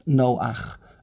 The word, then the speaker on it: Noach: Because when you have a patach underneath a hey at the end of the word, it's just like the letter ches at the end of the word with a patach. Everyone knows how to pronounce Noach. No, ach, aleph, ches. The ches is a shva noch.